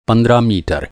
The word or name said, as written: पंद्रह